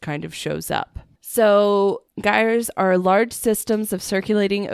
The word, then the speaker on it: gyres: But when people started tracking these, it taught the world a lot about, they're called gyres. I think that's how it's pronounced. It's G-Y-R-E-S.